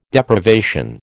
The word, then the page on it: Deprivation: American English